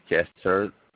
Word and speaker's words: Jester: My name is Chester, spelled J-E-S-T-E-R, last name Otero, O-T-E-R-O.